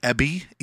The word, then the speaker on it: Ebi: So we got the Ebi, E-B-I.